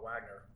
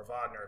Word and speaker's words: Wagner: It was a private military company called Wagner or Wagner, if I guess you pronounce it correctly, because it's named after a composer, a Russian military corporation.